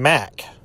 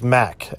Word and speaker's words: Mak: It's Dorian Mack. M-A-K. I thought at first maybe they mispronounced Maitha as Math. But it is Mack. M-A-K. So it has the same initials.